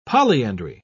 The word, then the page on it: polyandry: British English